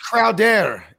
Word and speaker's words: Crowder: And Bogart, it's pronounced Crowder.